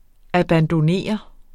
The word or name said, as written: abandonnere